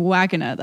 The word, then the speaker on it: vagina: They're actually pronounced penis and wagina, though.